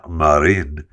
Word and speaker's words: Marin: I don't even know if they played that at the nightclub that Finland's prime minister, Sanna Marin, I get to Marin, M-A-R-I-N. I don't think she pronounces it Marin in Finland, but she's their prime minister, the youngest prime minister, as a matter of fact, in the world.